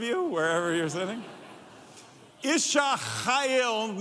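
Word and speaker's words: Chayil (חיל): Everyone say Isha and say, it looks like Chayil on here, but it's pronounced, this is a fun one to say, and that's why I'm letting you do it. Chayil. Everyone say Chayil.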